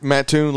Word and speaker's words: Mattoon: Is that Mattoon? Is that how you pronounce that? – Mattoon, Lake Mattoon.